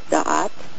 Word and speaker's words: Da'at: There needs to be like a little apostrophe between the A's in there for the pronunciation in Hebrew of Da'at.